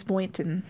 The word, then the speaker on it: Boynton: Her name was Janice Boynton. – Okay. – Am I saying that correctly? – Yeah, I think so. – Okay. B-O-Y-N-T-O-N.